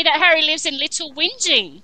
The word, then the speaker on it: Whinging: Little whining, that's how I pronounce it too, yeah. It wasn't until I heard it on something that I realized it was whinging, yeah. – Yeah, I never knew you actually said the G.